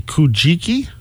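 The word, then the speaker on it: Kojiki: The Kujiki? texts. – Hey, got it again. Excellent. – It was luck. It looks like it sounds.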